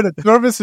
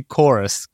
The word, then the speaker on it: Chorus: Chorvis is just what you tricked me into thinking the name of the game was. It's actually Chorus. – Chorus. – The V is pronounced like a U.